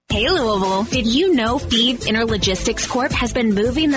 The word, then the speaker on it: Fives: Feed is spelled F-I-V-E-S, like the number, but pronounced Feed.